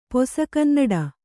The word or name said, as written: ಪೊಸಗನ್ನಡ